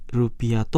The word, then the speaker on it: Rp: 話しされるとき記号 RP はお金の数の後にルピアと発音されます。